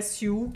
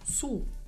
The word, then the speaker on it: Sioux: só queria lembrar que a pronúncia não é Siu, que, como a gente acha que é, mas é Sul. Sul? É.